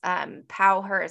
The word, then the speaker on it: Powher: It's Powher is spelled P-O-W-H-E-R.